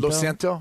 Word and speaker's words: Lo siento: Lo siento? – Lo siento? – Yeah, that was correct.